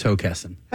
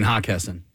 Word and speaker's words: Hockessin: Well, the C is silent. – I don't know. Or there's like an E missing. – All right. – Yeah, all right. I get it. – So, Hockessin.